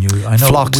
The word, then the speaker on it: phlox: Phlox, we've talked about that. – Yeah, the P is silent in phlox. It's P-H-L-O-X.